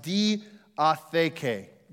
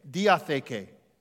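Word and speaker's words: diatheke (διαθήκη): So the covenant, the Greek word for this, for the word covenant, is pronounced diatheke, diatheke.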